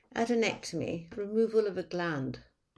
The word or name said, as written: adenectomy